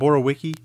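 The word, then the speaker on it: Borowiecki: Mark Borowicki. Is that Borowicki? Am I saying that correctly? – Sure.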